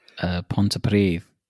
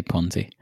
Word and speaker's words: Pontypridd: Pontypridd. – Pontypridd. – But if they weren't Welsh speakers, they would probably say Ponty.